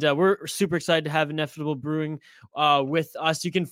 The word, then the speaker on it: Ineffable: And I think you added an extra in there. – I know, Hoppy was about to roast me again. Ineffable Brew. – Ineffable. I'll spell it for everyone. Ineffable. There's no D.